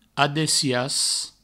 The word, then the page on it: adessiatz: Provençal; adessias